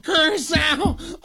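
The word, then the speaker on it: Curaçao: Curacao. – Oh, they've really earned our respect. Man, they're getting their heads beat in. Throw in the towel. Just all the goofy announcers. Oh, Curacao.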